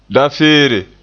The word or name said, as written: dafeere